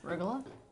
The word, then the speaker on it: arugula: No, how do you pronounce this? – Arugula. – Thank you. That's what it Arugula.